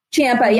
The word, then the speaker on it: Ciampa: And the confrontation with, I'm sorry, how do you pronounce his last name again? Tommaso's. – Ciampa.